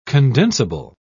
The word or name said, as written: condensable